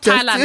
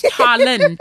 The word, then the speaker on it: Tallinn: She's not saying Thailand, just to be sure. It's not Thailand, it's Tallinn. Tallinn, yeah.